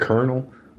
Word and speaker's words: colonel: If you didn't speak English and you read the word colonel, where do you get? How do you pronounce that colonel? – Colonel. – There's no R. There's no R in colonel.